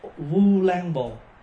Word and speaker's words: Vu Lan Bồn: Đúng ra là Ulambana hay là Ulambồn, mà nhưng mà mình đọc trại ra thành Vu. Chữ Vu bây giờ là thế chữ ô đó, U Lam, thì mình đọc là Vu Lan Bồn.